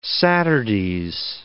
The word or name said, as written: Saturdays